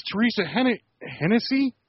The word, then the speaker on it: Hennessy: Her name is Teresa Hennessy. – All right. – I think I'm saying that right. – That's the correct pronunciation of it.